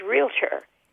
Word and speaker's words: realtor: And another word, and that I think that is all over the United States, that I would say 90% of people mispronounce, and that is realtor. Everybody realtor. – Yeah, you want a vowel between the L and the T, don't you? Your tongue like almost demands a vowel there.